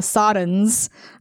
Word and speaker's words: Psadans: how do you say that with the Silent P. – Satans. – I don't know. – Like Psalm, like just Silent P. – Okay, the Minor Ishii and the Sodans are wanting to confront them, and the Nogri have been keeping them from doing that.